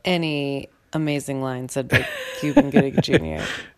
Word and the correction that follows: Cuba Gooding Jr.: Cuba Gooding Jr. I can't say his name. Cuba Gooding Jr. You did it.